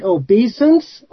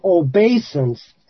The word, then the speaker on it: obeisance: Just if it's easier for the friends, I looked it up and it says that you could pronounce it obeisance or obeisance, and if it's easier to remember what it means by obeisance, it's properly pronounced that way.